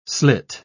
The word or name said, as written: slit